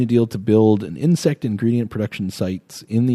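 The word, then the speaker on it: Ynsect: It's Y-N-S-E-C-T, but I believe it's still pronounced insect.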